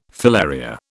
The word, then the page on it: filaria: British English